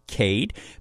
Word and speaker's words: KVDE: Today's artist hails from Dallas, Texas, goes by Cade, spelled K-V-D-E, pronounced Cade. Back in 2025, he experienced a little bit of a downturn and decided to do something about it.